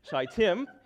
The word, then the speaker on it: Shittim: We researched that a lot to just say that's how you pronounce Shittim, and went to the Jordan, where they camped before crossing over.